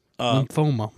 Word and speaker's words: lymphoma: Tucker out of lymphobia? – Yes. – Lymphomia? Lymphoma, my bad. I've said it wrong this whole fucking time.